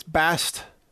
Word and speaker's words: Bastyon: I'm on Bastion, which is Bastyon. It's easy to remember because the Y-O-N is spelled the name as Michael Yon. His last name is Y-O-N. So it's Bastion. Bastyon.